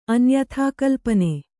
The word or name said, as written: ಅನ್ಯಥಾಕಲ್ಪನೆ